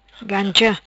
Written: Gancha

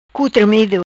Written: Cu Tremido

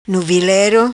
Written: Nuvilero

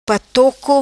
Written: Patoco

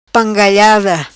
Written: Pangalhada

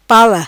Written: pala

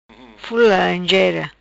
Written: Fula Laranjera

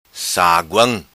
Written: Saguam

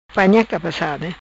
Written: Cabecada panha

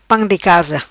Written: Pam di casa

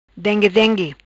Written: Dengue Dengue

